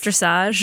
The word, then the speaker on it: dressage: Dressage. – Yeah. That's how it's pronounced? – Yeah. – Wow. My whole life, I thought it was dressage.